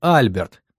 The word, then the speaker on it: Альберт: Потом сложили цепь событий воедино и поняли, что главный виновник — некий Альберт, ударение на «А», брат Майкла.